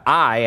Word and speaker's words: Ai: Unbeknownst to Joshua, in verse 2, now Joshua sent men from Jericho to Ai, or to Ai, actually, is how it is pronounced, which is near Beth-Aven to the east of Bethel, and told them, go up and spy out the region.